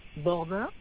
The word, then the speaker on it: Bordin: Bordin, B-O-R-D-I-N, Isa.